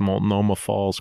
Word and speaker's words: Multnomah Falls: He even pronounced Multnomah Falls correctly.